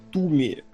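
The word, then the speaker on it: Twomey: Аниматор, я, кстати, вспомнил, э, почему я сразу не зацепился за него, потому что Нора Туми, Туми, она правильно произносится, она работает...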